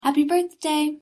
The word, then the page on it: happy birthday: English